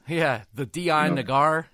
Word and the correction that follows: Dianoga: Whatever. – You got the R in there. – Dianoga was like, hey, there's no R there.